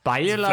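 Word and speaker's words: Beyeler: Und okay, Beiler, das habe ich ja gemacht. Das habe ich ja gemacht. – Beiler also Deutsch.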